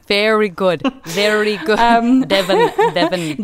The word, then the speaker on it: Devon: And we all moved to Devon. I'm pronouncing it quite hard there because oftentimes people think I say Dublin in Ireland.